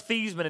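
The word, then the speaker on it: Theismann: His name was Thiesman until he trained to try to rhyme with Heisman to try to win the Heisman.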